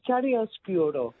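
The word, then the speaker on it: chiaroscuro: I just remember Chariscuro. – Beautiful pronunciation.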